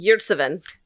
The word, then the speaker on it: Yurtseven: How do you say his name? Yurtseven? – Yurtseven.